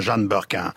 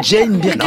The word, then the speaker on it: Jane Birkin: Maréchal, avez-vous compris pourquoi Jane Birkin était notre invitée? – Affirmatif, mon cher Nagui. Bien sûr que je sais pourquoi nous invitons Madame Jeanne Birkin. – Non, Jane Birkin.